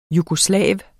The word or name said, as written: jugoslav